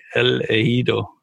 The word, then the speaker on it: El Ejido: And they play this weekend against El Ejido. I think I said that right. El Ejido, right? Yeah. I got it. Yeah. I nailed it.